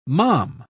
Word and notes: malm: British English

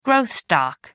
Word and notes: growth stock: American English

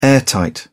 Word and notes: airtight: British English

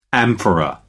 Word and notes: amphora: American English

